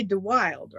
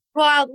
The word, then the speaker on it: de Wilde: But you say DeWilde, right? – Wild. Well, actually in Dutch, when I'm in Holland, that's how they pronounce it, is DeWilde.